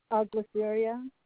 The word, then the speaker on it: Gliceria: Gliceria, Gliceria.